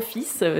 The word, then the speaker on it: fils: C'était pas du tout la première fois de ma vie, mais je me suis dit, putain, pourquoi ça se prononce fils alors qu'il y a un L au milieu ? Et pourquoi ça se prononce différemment du mot fil, qui est un mot qui s'écrit pareil.